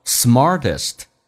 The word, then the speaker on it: smartest: who is the smartest， t 要浊化成 d 这个发音，因为在美式发音当中两个元音中间所加的辅音要被浊化，叫做 smartest。